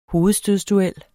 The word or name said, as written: hovedstødsduel